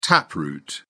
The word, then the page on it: taproot: British English